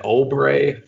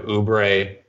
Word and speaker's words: Oubre: We had an Obre from Ken instead of Oubre. Pretty similar to Ombre, though.